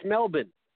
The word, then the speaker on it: Melbourne: It's Melbourne. They don't pronounce Melbourne. – The R. – They don't. That is correct.